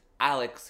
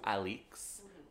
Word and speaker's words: Alix: It's spelt in the book A-L-I-X, so I was just, like, gonna say in my mind that it was Alex, but no, it's Alix, and that's just part of her whole satire of it.